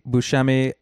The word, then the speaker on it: Buscemi: And my name is Andy Buscemi. The last name is spelled B-U-S-C-E-M-I.